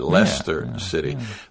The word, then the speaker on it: Leicester: You wouldn't think Leicester when you read that, but Yeah, it's pronounced like L-E-S-T-E-R, Leicester City.